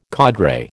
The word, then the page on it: cadre: American English